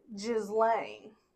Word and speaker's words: Ghislaine: There's two pronunciations depending on what part of the world you're from. So if you're French or European of some sort, they tend to say Ghislaine.